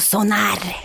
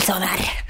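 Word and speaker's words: Sónar: Du uttalar det så norskt bara. Mm. – Den kan... Sonar. Mm. El festival Sonar.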